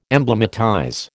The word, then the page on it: emblematize: American English